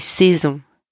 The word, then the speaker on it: saisons: And you don't pronounce the S at the end. Les saisons.